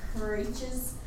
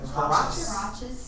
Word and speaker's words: huaraches: They were huaraches. – How do we pronounce that? – Huaraches.